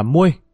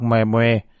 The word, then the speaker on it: Moe: Lão này tên là Moe, đọc là Muê, thím nào đọc mè mè là sai rồi.